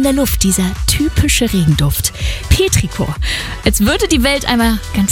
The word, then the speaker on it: Petrichor: Petrichor.